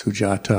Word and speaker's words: Sujata: So you have to pronounce it as an anthill or sujata, never as in they. So it's sujata.